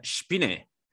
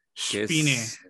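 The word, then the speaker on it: Spinne: Eh, pero el, el, nombre de la banda se pronuncia Spine. – Spine. – exactamente, que significa araña en alemán.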